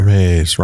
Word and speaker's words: Termez: Don't care. Termez rhymes with Hermes.